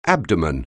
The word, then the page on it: abdomen: anglais